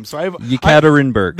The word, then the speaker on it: Yekaterinburg: Yekaterinburg.